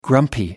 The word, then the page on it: grumpy: American English